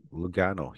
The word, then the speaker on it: Lugano: She is signed with Switzerland, am I pronouncing Switzerland L Lugano? – Yeah. – Lugano? Lugano Ladies. – Lugano? – Lu Yeah. – Lugano.